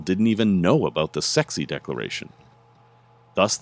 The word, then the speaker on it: SCSI: The creator, Larry Boucher, or is that Boucher, intended for it to be pronounced sexy. But nearly everyone saw SCSI and pronounced it scuzzy.